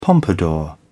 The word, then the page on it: pompadour: British English